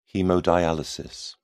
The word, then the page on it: haemodialysis: British English